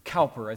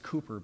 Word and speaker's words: Cowper: You may have heard of William Cowper. I think it's pronounced Cooper, but it looks like Cowper, C-O-W-P-E-R.